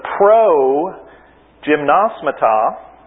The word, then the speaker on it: progymnasmata: And the pro gymnasmata, that's the correct pronunciation. Everybody say it. Progymnasmata. Yeah, put the accent on the right place.